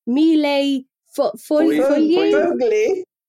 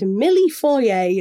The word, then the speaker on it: millefoglie: Say it properly. – An Italian millefeuille, which is a mille foglie. – Foglie. – I swear at one point Paul just said milli foyer, and I was like, I'm pretty sure that's not quite right.